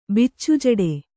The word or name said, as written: ಬಿಚ್ಚುಜಡೆ